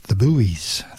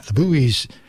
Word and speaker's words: Buoys: Here's a song by a band called The Boys, but it's spelt B-U-O-Y-S. The Buoys. The Buoys.